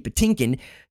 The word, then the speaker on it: Patinkin: Mandy Patinkin, he stepped into a major role.